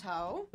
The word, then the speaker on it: Haux: It's called House Ho.